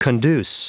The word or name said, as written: conduce